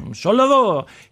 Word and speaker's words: Charlevoix: Frisky is a Republican from, how do you say it? Charlevoix? Okay, you know, it was Vaux or Voy. It's French, huh? – Charlevoix.